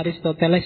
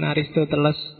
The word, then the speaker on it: Aristoteles: Bacanya Aristoteles ya, bukan Aristoteles. Ya.